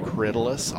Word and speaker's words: Corydalis: It's spelled Corydalis, but it's pronounced Corydalis, all one word.